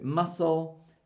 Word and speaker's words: muscle: So the letter C is silent in SC word combination, and C is useless in front of a K or a Q. So muscle, scissors, ascent, miscellaneous. We don't say muscle, we don't say scissors, we don't say askent, and we don't say miscellaneous.